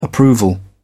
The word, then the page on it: approval: British English